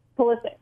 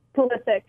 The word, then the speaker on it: Pulisic: Okay, now, one more time, last name specifically is pronounced Pulisic. – I want to make it Pulisic so bad, and I need to put the emphasis on the second syllable, correct? – Pulisic.